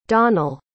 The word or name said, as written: donnell